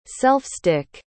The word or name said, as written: self-stick